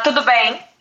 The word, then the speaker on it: Tudo bem: Tudo bem. – Tudo bem? Did I say that right? – Yeah. – Did I put the accent on there properly? – Yeah. Perfect. Okay.